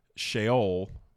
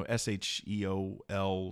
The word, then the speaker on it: Sheol: Now, you can find translations that will translate the Jewish, the Hebrew word Sheol. – Yep. You know, S H E O L, Sheol.